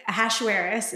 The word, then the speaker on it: Ahasuerus: And the story opens with King Ahasuerus, is how you pronounce his name.